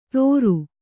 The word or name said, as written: ತೋರು